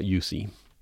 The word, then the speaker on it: Jussi: So here I'll tell the story about Yusi. By the way, you'll see in the title, his name is spelled J-U-S-S-I, but in many other languages, the J is a Y sound.